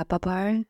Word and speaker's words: Babar: Babar? – My French is also superb, but I think, yeah, Babar, the elephant.